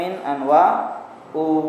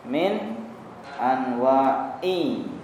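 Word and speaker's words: min anwa'i (من أنواع): Wa ghairu dzalika, dan lain sebagainya, min anwa'il ibadati, dari macam-macam. Min huruf jar. Jadi min anwa'i, jangan dibaca min anwa'u, jangan dibaca min anwa'a. Yang betul apa? Min anwa'i, karena min huruf jar ya, min huruf jar.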